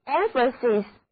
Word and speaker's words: emphasis: 所以 emphasis 的意思就是重要性或者强调重点。元音字母 A 的读音是短的，而两个字母 S 都读作清辅音，整个单词重音第一个音节，一起来读两遍吧。